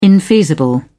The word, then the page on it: infeasible: British English